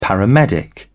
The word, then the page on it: paramedic: British English